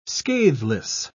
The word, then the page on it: scatheless: British English